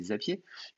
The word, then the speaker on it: Zapier: Après, euh, Zapier, euh, du coup, euh, prononcer à la belge, ça fait Zapier.